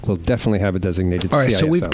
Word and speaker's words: CISO: They call it a CISO. – A CISO. – Yes, they love to call it, they love pronouncing acronyms.